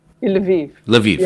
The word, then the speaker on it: Lviv: I believe you're also in, I'm not sure if I'll pronounce it right, but Lviv? – Lviv.